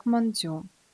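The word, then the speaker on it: мандзю (まんじゅう): Китайский иероглиф «мантоу» на японском записывается аналогично, но читается как «мандзю».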